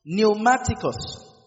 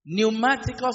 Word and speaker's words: pneumatikos: The word spirituals there is the Greek word pneumaticos. Pneumaticos is spelled P-N-E-U-M-A-T-I-K-O-S. Pneumaticos.